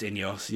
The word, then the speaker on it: Ineos: is it Ineos? – I think it's pronounced Ineos, yeah.